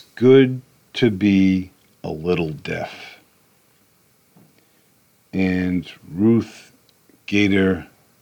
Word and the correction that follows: Bader: Ginsburg, is it? I think I pronounced it right. Ruth Bader Ginsburg.